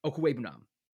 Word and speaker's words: Okwuegbunam: Is that how you say it? Okwuegbunam. – Nailed it.